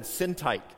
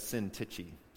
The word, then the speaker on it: Syntyche: I'd always said Syntyche, but I learned this week that it's Syntyche.